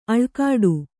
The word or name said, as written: ಅಳ್ಕಾಡು